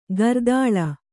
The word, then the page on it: ಕರತಾಳ: karatāḷa